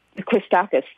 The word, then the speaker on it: Christakis: But I agree with Dr. Christakos, if I mispronounced it, that Christakis, thank you,